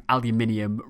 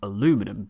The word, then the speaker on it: aluminium: The British say aluminium rather than aluminum because we spell it with two I's.